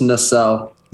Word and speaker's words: Nacelle: Is that how you pronounce it? – I've been pronouncing it Hollow Nacelle, just Nacelle. – Nacelle. – So I hope it's pronounced that way.